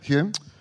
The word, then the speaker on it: Hume: Hamilton Hume. Am I saying that right? Hume? – Yep, Hume.